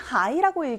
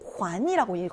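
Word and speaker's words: 환이라고 (还): 자, 얘는 하이라고 읽지 않고 환이라고 읽어야 돼요.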